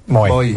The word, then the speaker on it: Mooy: Moy.